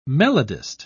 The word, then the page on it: melodist: British English